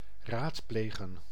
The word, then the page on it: beraten: [bəˈʁaːtn̩]; [bəˈʁaːtən]